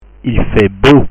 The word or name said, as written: il fait beau